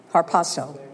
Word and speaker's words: harpazo: That's the Greek word harpazo. Rick Renner, I don't know how to pronounce it. H-A-R-P-A-Z-O, harpazo.